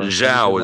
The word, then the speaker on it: Zhao: how amazing of a job Chloe's, is it Zhao or Zhao? Or Zhao is how I've heard it pronounced the most.